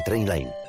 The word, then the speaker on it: Trainline: Se escribe mejor precio garantizado. Se pronuncia Trainline.